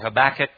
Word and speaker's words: Habakkuk: Don't have time to talk about the whole context of this minor prophet Habakkuk, but it's very interesting, or Habakkuk, depending on how you pronounce it.